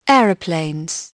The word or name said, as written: airplane